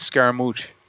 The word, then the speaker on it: Scaramouche: Scaramouche? Is that how you say it? Scaramouche? – I think it's Scaramouche. – Scaramouche. Yes.